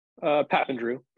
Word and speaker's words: Papandrew: Papandrew, pretty close.